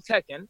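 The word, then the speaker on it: Tekin: Once you learn how to say it, it's actually not that hard. It's like the game Tekken, right? It's Alp Tekken.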